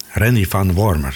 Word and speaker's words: Randy Van Wormer: Randy Van Wormer, zo moet ik het uitspreken.